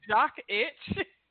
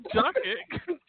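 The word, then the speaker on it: Jokić: Jokic. – It's not like you say it, Jock Itch. – Wow. – Baby. – Jokic. Like the Joker. You call him Joker.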